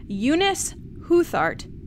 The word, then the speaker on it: Eunice Huthart: So this Thursday, we're joined by stunt coordinator for The Rise of Skywalker, Eunice Huthart.